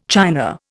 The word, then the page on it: china: Indian